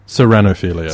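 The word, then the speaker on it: ceraunophilia: So you must have a touch of chlorinophilia. Is that how you would pronounce that word? – Again? – Serranophilia? – Serranophilia. Serranophilia. Sounds a bit lighter.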